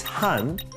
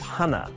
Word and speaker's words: 한: When counting like this, the last letters aren't pronounced. It's han instead of hana, tu instead of tu, se instead of set, ne instead of net, and sumu instead of sumul.